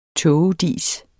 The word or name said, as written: tågedis